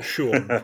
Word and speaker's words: Schuon: Uh, so why don't you pronounce Schuon properly? You've got it right.